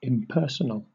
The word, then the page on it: impersonal: British English